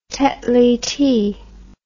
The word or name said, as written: Tetley tea